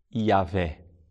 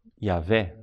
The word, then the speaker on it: Yahvé: Provavelmente a pronúncia do nome seria Yahvé.